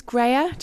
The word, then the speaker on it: Graihagh: So my name's Graihagh, just in case you're wondering how to pronounce it.